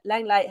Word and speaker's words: Lenglet: that Lenglet has joined Tottenham Hotspur. – Well, it'll be Lenglet to my dad,